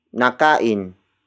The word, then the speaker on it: nakain: Nakain niya ang tanghalian. Nakain niya ang tanghalian. The first word is nakain. Nakain. Nakain. Nakain is spelled as N-A-K-A-I-N.